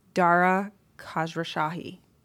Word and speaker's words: Dara Khosrowshahi: Dara Khosrowshahi.